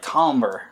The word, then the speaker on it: tomber: It looks like timber, but it's like tomber.